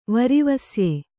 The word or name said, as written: ವರಿವಸ್ಯೆ